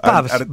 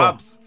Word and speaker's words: pubs: Pubs, bares, viejo. – Pubs. Bueno, pubs. Pronuncie bien, para eso es locutor.